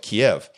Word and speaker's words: Kiev: You know, when I was growing up, it was always pronounced Kiev, you know, two syllables, Kiev, with sort of an emphasis on the last syllable. Now, all the news reporters, they say Kiev, you know, just one single syllable as though it were spelled K-E-E-V, Kiev. That's the way they say it. Now, I think it's interesting looking at the mug that I have here.